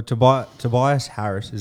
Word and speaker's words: Tobias Harris: Well, Tobias Harris, is that how you say it? – Yep.